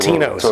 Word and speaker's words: Totino's: that's old Tostano's, whatever, pizza Totino's.